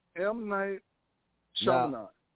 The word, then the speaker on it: M. Night Shyamalan: And now say his name. Say his name again. – M. Night Shyamalan.